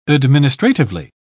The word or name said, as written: administratively